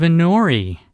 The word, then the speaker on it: Inori: And in the studio right now, we have Inori.